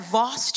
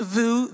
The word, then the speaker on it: Vous: Vous means you in French, but it's often mispronounced. I love when I'm telling people about church and they're like, "Oh, you go to that Voss church?" And I'm like, "Well, Voss is a water, but we have living water, so it's kind of the same thing." Or this is my favorite one. "Do you go to the Voss church?" So I don't know who this one is for, but it's pronounced VU. The S is silent.